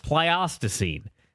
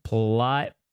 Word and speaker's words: Pleistocene: She says it's Pleistocene. I must have pronounced that wrong. Pleistocene. She's trying to help me out here. Pleistocene. There we go.